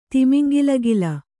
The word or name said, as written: ತಿಮಿಂಗಿಲಗಿಲ